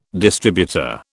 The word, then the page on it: distributor: British English